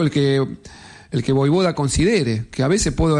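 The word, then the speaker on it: Vojvoda: Y además confirmando que su apellido es Voivoda. – Claro. Se pronuncia de esta manera.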